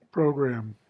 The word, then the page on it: program: American English